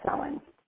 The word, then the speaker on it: Samhain: but it's according to the old Gaelic language, which is a Samhain. Or, I'm sorry, Celtic language, and it's pronounced Samhain.